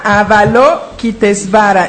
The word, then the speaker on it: Avalokitesvara: Y salió Avalokitesvara. ¿Sí lo pronuncié bien? Avalokitesvara. – Sí, esta es una deidad. Se llama Avalokitesvara, exacto.